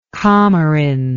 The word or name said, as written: comorin